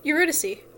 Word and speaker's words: Eurydice: Eurydice.